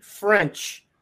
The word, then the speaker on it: Ffrench: Alpha Dog named Jamie Ffrench. Not spelled like you'd think. Jamie, in fact, FF, it's actually J-A-I-M-E, FF. R-E-N-C-H.